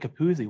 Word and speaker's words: Capuzzi: We always said it rhymes with jacuzzi, which is actually the American pronunciation of Capuzzi, which is the Italian pronunciation.